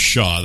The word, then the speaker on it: Shaw: My last name is Shaw. That's one syllable.